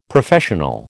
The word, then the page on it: Professional: American English